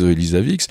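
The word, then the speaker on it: Elsevier: Et puis, on est face à une entreprise, alors médecine, je pense qu'il faut le prononcer à l'anglaise, Elisavix, un leader international de l'information médicale.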